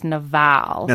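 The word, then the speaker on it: Nabal: His name, the Hebrew pronunciation of his name is Naval.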